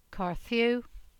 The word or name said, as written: Carthew